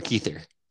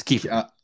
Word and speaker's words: Kiefer: Is it Kiefer or Keither? It's Kiefer. – It's Kiefer. Sutherland. With an F. Yeah, yeah. Keither isn't a name. – No, Keither is definitely not.